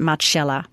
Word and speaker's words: Marcella: It is called Marcella, or as they pronounce it, Marcella.